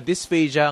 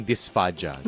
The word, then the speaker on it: dysphagia: So, dysphagia ang pronunciation sa atin, pero pwede rin dysphagia. – Yes, pwedeng dysphagia, pwedeng dysphagia. Commonly, dysphagia.